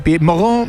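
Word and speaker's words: Pierre-Mauroy: Pied Moreau, si quieren la pronunciación francesa.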